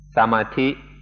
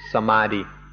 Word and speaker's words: samadhi: Thai pronunciation is samadhi, and often you'll hear foreigners pronounce it samadhi.